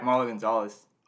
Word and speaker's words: Marlowe Gonzales: Marlowe Gonzales.